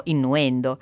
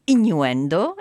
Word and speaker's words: innuendo: Che tra l'altro noi naturalmente, noi italiani, vedendo questa parola la pronunceremo in un modo completamente diverso, perché è di chiarissima origine latina, quindi noi diremmo innuendo. E invece giustamente sono lettere che agli inglesi richiamano agli americani in questo caso, perché avrete sentito che l'accento della nostra voice coach in questo caso è americano, e lei dice quindi today's word is innuendo,